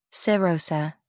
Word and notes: serosa: American English

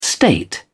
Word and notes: state: British English

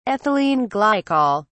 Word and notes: ethylene glycol: British English

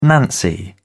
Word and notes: Nancy: British English

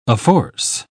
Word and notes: afforce: British English

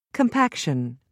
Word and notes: compaction: British English

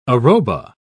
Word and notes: arroba: British English

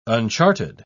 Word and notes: unchartered: British English

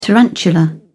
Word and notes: tarantula: British English

